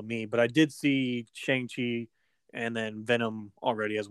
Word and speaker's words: Shang-Chi: I saw Venom and I saw, I think it's, is pronounced as Shang-Chi. Yeah, it might be, but like Shang-Chi was, that's right.